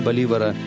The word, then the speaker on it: Боливар: Но планам Боливара не суждено было осуществиться. 17 декабря 1830 года он умер от туберкулеза. В Европе его имя произносит с ударением на последний слог – «боливар».